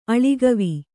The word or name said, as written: ಅಳಿಗವಿ